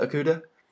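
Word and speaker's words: Okudah: Is it Okuda? – Yeah, Is that how you pronounce it? Okuda? – Yeah, Jeff Okuda.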